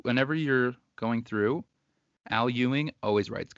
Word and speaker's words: Ewing: Ewing.